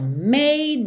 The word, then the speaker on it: made: Então, made me sad. Aqui, também o passado de make é made, M-A-D-E, mas esse E não é pronunciado.